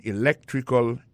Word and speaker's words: electrical: I majored in electrical engineering. Electrical engineering. Ça veut dire accent tombé dans electrical, là, c'est sur deuxième syllabe là. Electrical engineering. C'est sur deuxième syllabe là. Elect là. Plus appuyé. Electrical engineering.